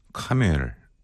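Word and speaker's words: Camel: 오늘은 카멜, 캐멀? 아, 이게 참 발음이 어렵네요. 캐멀? 예, 롱 굿바이 들으면서 오늘 방송 마무리하겠습니다. 카멜이라고 한다고 틀리는 건 아니잖아요. 그렇죠? 예, 카멜.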